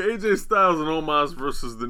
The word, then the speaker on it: Omos: New Day, Kingston and Woods defended against AJ Styles and Omos, if I'm saying that right? – Omos.